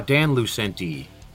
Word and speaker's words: Lucente: Dan Lucente.